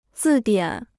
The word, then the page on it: 字典: zì diǎn